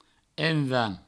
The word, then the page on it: envan: envans; provençal